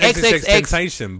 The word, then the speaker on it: XXXTentacion: So when Triple XXX Tension, whatever his fans, I don't know how to pronounce his name. I know I'm saying it wrong. – Yeah, no, it's XXXTentacion, but I mean, I don't fucking care how you say his name.